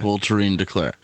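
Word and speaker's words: Voltairine de Cleyre: V-O-L-T-A-R-I. – Close. – I-N-E. V-O-L-T. Go ahead. – V-O-L-T-A-I-R-I-N-E. – D-E-C-L-E-Y-R-E. Yeah. – Voltairine DeClaire.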